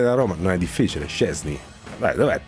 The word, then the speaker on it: Szczęsny: Szczęsny. Okay. Quite okay. Thank you. Szczęsny.